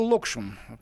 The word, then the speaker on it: Локшин: Нет, Э, Павел Локшин, так правильно делается ударение в его фамилии, из немецкой газеты Die Welt написал следующее.